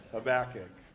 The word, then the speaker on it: Habakkuk: But here on the shore, we say it correctly. Habakkuk. I want to avoid relentless criticism of the way I pronounce that word, because it happens. Habakkuk, not Habakkuk.